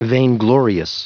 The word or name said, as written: vainglorious